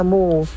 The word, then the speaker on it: moo: Misalnya M-O-O, ya kan? Itu kan kalau bisa dibaca mo ya di bahasa Indonesia. – Iya, mo. – Tapi ketika O-nya ada dua, dibacanya mu.